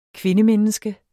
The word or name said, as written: kvindemenneske